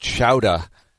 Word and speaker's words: Choudja: Spelled C-H-O-U-D-J-A, but it's pronounced Chowda, which I'm just praying he makes the team.